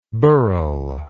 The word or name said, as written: burrhel